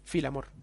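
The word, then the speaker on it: féileadh mòr: Vale, la parte, o sea, cuando el kilt era que se pasaba por, la, su pues, hombro, se llama Filamor. – Filamor. – Fe, pero se pronuncia Filamor.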